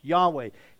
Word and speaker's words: Yahweh: And the aspect is that this is referring back to Yahweh, that term that we pronounce, Yahweh.